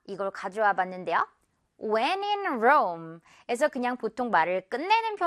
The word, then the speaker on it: Rome: Rome.